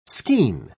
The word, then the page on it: skean: British English